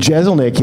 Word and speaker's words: Jeselnik: And I'm going to pick good name tags, not like those jerks, not like fucking Kyle Kinane and Jeselnik yesterday. That just how do you pronounce his name again? – Shit. Jeselnik? – Oh, okay. – Is how you say it? – Yeah, that's right.